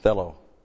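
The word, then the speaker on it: Thelo (θέλω): Thelo. This little word here, thelo. T-H-E-L, long O.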